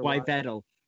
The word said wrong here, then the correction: Yveltal: Sorry, internet, but it's Yveltal. That's how I pronounce it.